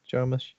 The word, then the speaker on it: Jarmusch: Jarmusch? – Yeah.